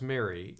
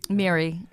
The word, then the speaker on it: Miri: Why don't you read, and it is pronounced Mary. – Mary. – Is spelled M-I-R-I.